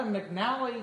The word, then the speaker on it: McNally: Was that a McNally? Is that how you pronounce it? – Yeah.